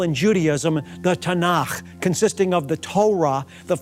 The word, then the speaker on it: Tanakh: where we get the word Tanakh,